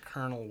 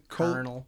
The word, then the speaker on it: colonel: I think colonel was, it's an Italian pronunciation but with a French spelling or vice versa. That's why we ended up with the impossible to spell colonel.